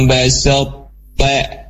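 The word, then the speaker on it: basata (بسطت): Yang hilang adalah kolkolahnya dari to. Ya kolkolah dari to-nya itu udah tersembunyi gitu ya. Nah, seperti ini cara bacanya, la im, la im basata, la im basata. Basat itu dalam kondisi tebal, dan ketika ka, maka dia menjadi tipis ya.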